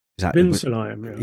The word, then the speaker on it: Ben Sulayem: It's Mohammed Ben Sulayem. – Ben Sulayem. Yeah, Ben Sulayem. – It can be Ben or Ben.